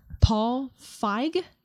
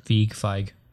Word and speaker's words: Feig: Paul Feig is how I would think it's pronounced. Yeah. Got it. – Feig.